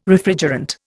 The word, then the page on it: Refrigerant: Indian